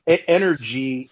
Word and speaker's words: NRG: NRG, and that's how it's spelled, NRG.